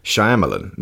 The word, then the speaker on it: Shyamalan: Night, with an N like Knight of the Hunter, rather than Knigget, like Knight in Shining Armour, and Shyamalan, not Shyamalama Ding Dong.